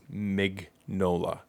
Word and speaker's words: Mignola: I'm on his Wikipedia right now, and if I'm reading the phonetic spelling of his name right, it's Mignola. – Mm-hmm. – So it is exactly the way it's spelled.